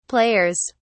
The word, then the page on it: players: British English